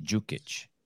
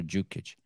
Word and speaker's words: Đukić: And that D is pronounced like, I always pronounce it like a J, Dukić. – Yes. – Is that how you pronounce it? – Yes. – Lazar Dukić.